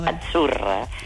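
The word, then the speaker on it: Azzurra: La zeta no es la zeta como es decimos en catalán tan sonora, no. Es achurra.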